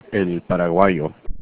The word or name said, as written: paraguayo